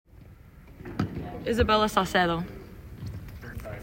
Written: Isabella Saucedo

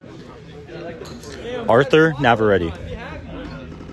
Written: Arthur Navarette

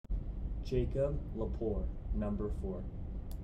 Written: Jacob Lepore

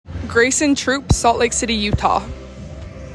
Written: Graysen Trupp

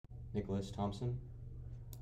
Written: Nick Thomson

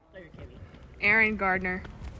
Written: Eryn Gardner

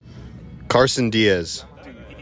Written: Carson Diaz

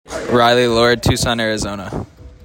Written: Riley Lord

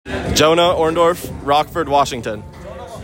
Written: Jonah Orndorff

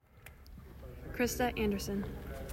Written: Krista Andersen

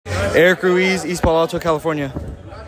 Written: Eric Ruiz-Cornelio

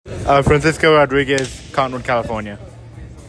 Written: Francisco Rodriguez

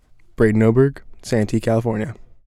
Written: Braden Oberg

